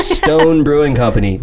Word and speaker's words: Stone Brewing Company: Number 9, we have Stone Brewing Company. Not Keystone. Not Sawstone. Not Stone Stone. Not anything that rhymes with stone.